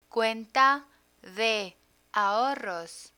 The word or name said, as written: cuenta de ahorros